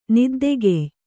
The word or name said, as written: ನಿದ್ದೆಗೆಯ್